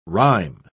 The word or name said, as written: rhyme